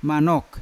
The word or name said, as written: manok